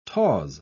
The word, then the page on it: tawse: British English